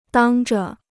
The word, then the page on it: 当着: dāng zhe